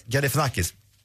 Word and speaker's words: Galifianakis: Barack Obama var ju då gäst hos, nu ska jag se, ni kanske kan uttala hans efternamn bättre, Zach Galifianakis, är det rätt? – Galifianakis.